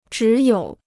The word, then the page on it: 只有: zhǐ yǒu